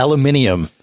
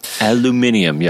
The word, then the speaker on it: aluminium: Aluminium. That's right. Yeah. – Aluminium. Yes. Say it like Johnny.